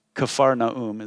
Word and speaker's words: Capernaum: Matthew 4:14 says that Isaiah 9, 1 through 2 were fulfilled when Jesus came to Capernaum, Capharnaum is the real pronunciation, and started preaching repentance and the kingdom of heaven in that Galilee of the Gentiles, formerly Galilee of the Gentiles.